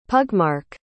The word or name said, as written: pugmark